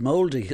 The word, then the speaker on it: Māori: Oh, I thought you said you're part Māori. You see, I didn't hear you properly. – No, I'm just pronouncing it right.